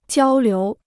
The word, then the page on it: 交流: jiāo liú